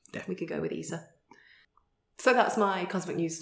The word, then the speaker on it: ESA: It's not pronounced ESA, they prefer ESA.